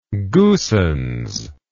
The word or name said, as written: goossens